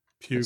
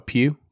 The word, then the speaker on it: Pugh: I don't know how to pronounce his last name. Poe, I'm going to say. P-U-G-H. – Pugh. – Sound like, oh, Pugh? Mm-hmm. Okay. Greg Pugh.